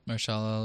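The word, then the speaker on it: Mahershala: actor in a supporting role will be, I hope I'm saying this right, but Marshala Ali. – Marshala Ali, yeah.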